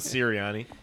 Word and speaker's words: Sirianni: I'm sticking with Sirianni. – Sirianni. – It's definitely, you got to say it like that, though.